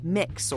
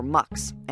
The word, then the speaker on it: Mx: It's MX, pronounced mix or mux, and it's designed for people who don't feel that a gendered title, like Ms., Mr., or Miss, fits their identity.